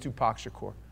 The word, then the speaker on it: Tupac Shakur: I know his name is Tupac Shakur.